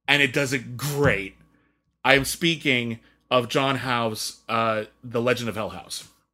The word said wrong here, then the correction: Hough: Might be John Huff. I'm actually not sure I pronounce his name. H-O-U-G-H. – Huff.